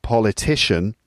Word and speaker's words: politician: But then a person, the person noun, that's politician. So politician. So the word stress is now on the third syllable.